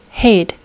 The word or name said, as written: hayed